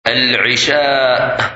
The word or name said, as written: Isha